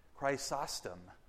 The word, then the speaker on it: Chrysostom: Chrysostom.